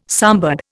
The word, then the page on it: sambar: Indian